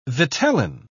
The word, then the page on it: vitellin: British English